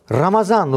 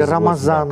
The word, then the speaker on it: Рамазан: Третье – это Рамазан, руза. – Или Рамадан еще, да? – Но Рамадан или Рамазан. – Кто как произносит.